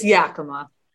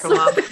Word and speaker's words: Yakima: So I need to ask you before we start, and we, can you just, for the record, tell us how we're supposed to pronounce it? Absolutely. It's Yakima. It's yes. Yakima. Yeah. Yakima. But I've heard a lot of people from the UK say Yakima, and I'm like, tee. But also, we're probably saying it wrong because it's like a native word.